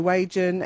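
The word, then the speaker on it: Wagin: Now, those G's, mate, they're the soft G's, so Wagon and Narrogin.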